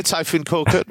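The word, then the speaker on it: Tayfun Korkut: Anyway, Hertha under new boss, Typhoon Korkut, which I probably butchered the name there. – No, Typhoon Korkut. Yeah, very good.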